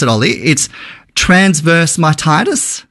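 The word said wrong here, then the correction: myelitis: Mylitis? – Transverse mylitis, yes.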